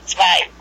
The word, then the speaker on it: zwei: Well, in Germany, it's part zwei. – Oh, part. – You say zwei.